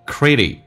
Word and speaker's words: critical: 那么这边有一个单词叫 critical, critical 指的是关键的，在关键的时刻，人生的关键的时刻 ,crit 发 d 这个发音 ,critical, critical,舌尖抵住你的上齿龈 ,critical moments, moments 跟 of 有一个连叫 moments of their life.